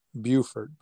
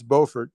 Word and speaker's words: Beaufort: Now, like in South Carolina, there's a town of the same spelling, and they pronounce it Beaufort. But in North Carolina, as I was told, it's Beaufort. And really a cool town, Rocky.